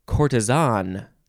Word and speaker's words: courtesan: Next word is courtesan or courtesan or courtesan. You know, a few ways to pronounce those syllables. And then the British say courtesan. They emphasize the last syllable.